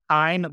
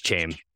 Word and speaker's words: Chaim: It's like Heine Bloom. – Nah, it's Chaim. It's Chaim, bro. He is a shame. So it's Chaim.